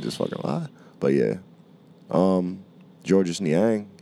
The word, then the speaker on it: Georges Niang: Georges Niang. – He said the S is silent. – The S is silent? – He said it himself, yeah.